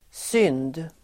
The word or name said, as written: synd